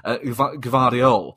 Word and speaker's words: Gvardiol: Gavard, apologise for butchering the pronunciation, Gvardiol.